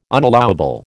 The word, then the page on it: unallowable: American English